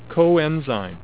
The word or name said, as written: coenzyme